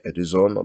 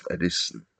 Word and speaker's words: Edison: Takže Vítězslav Nezval a jeho proslulá básnická skladba Edison. Budeme vyslovovat důsledně počeštěně Edison, abychom si vychutnali některé na to navazující Nezvalovy rýmy a vzdáme tedy hyperkorektní výslovnost Edison.